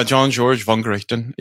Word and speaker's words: Jean-Georges Vongerichten: So you start off the book with a case study from this chef named, I'm going to get his name right, is Jean-Georges Vongerichten. Vongerichten? – Pretty close. Yeah. Jean-Georges Vongerichten.